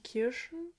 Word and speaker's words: Kirschen: Wolltest du nicht Kirschen? – Kirschen. Aber das ist Jans.